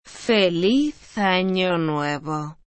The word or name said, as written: feliz ano nuevo